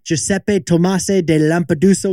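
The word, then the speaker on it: Giuseppe Tomasi di Lampedusa: Because like Giuseppe Tomasi de Lampedusa once wrote, perfect pronunciation.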